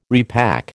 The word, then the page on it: repack: American English